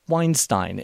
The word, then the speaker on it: Weinstein: The speaker was Eric Weinstein, and yes, that rhymes with Einstein.